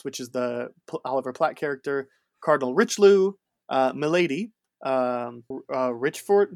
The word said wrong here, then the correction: Rochefort: Did I say that right? – Like Rochefort.